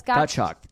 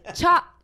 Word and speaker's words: Gottschalk: Like, yeah, it's Gottschalk. Gottschalk. – You always say chalk. – Chalk.